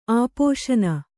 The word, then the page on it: ಆಪೋಶನ: āpōśana